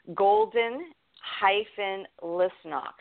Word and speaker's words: Golden-Lisnock: So my name is spelled Stacey with an E, S-T-A-C-E-Y. Golden-Lisnock. So it's Golden, G-O-L-D-E-N, hyphen Lisnock is L-I-S, like Sam, N like Nancy, O-C-K.